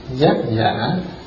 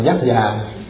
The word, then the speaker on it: giác giả: giác giả. Giác giả tức là cái người giác ngộ đó. Cái này viết nhanh quá, cô kia có đọc là giác già.